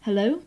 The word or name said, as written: hello